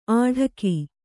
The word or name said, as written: ಆಢಕಿ